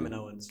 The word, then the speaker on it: Kaminoans: Kaminoans. Kaminoans. Thank. Oh, is that how you say it? Kaminoans.